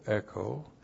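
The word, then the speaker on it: echo (ἔχω): Now echo, looks like this, E-C-H, long O, echo.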